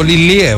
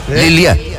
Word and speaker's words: Lille: Tiene mejor pronunciación del francés, ¿verdad? – Sí, él es francés. – ¿Cómo el equipo? Lillier, ¿verdad? – Lillier. – Ahí está.